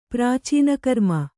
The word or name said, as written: ಪ್ರಾಚೀನಕರ್ಮ